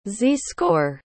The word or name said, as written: z-score